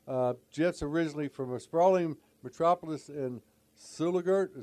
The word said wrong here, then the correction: Sulligent: That is, uh, it's hard one to pronounce. It's Sulligent, Alabama. Sulligent.